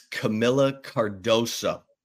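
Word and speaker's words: Kamilla Cardoso: D-U-N, done. By the way, I butchered our girl's name. It's Camilla Cardoso.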